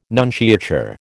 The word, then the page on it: nunciature: American English